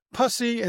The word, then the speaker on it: pussy: The Post, I wasn't at the Post then, but the Post decided to go with pussy and in parentheses rhymes with fussy.